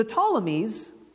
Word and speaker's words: Ptolemies: So the Ptolemies, and I'm not sure why it begins with a P, but we pronounce it with a T.